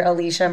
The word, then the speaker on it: Elicia: I'm under Alicia Merwin Edwards. That's my maiden name and my last name. Alicia is spelled E-L-I-C-I-A because it's not how it sounds.